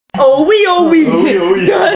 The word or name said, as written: Oh oui, oh oui!